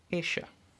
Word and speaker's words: ella: And to say she, you say Esha, which is actually spelled E-L-L-A, even though it doesn't sound like it. Esha.